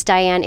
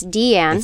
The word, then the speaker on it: Diane: I thought it was Diane. It is Deanne.